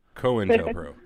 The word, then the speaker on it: COINTELPRO: I pronounced it COINTEL. – COINTEL, huh? It's COINTELPRO.